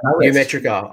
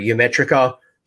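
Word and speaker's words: Ymetrica: So yeah Ymeritra. Ymeritra. I'm going to make sure that I say it correctly,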